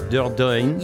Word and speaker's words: Dordogne: Dordogne.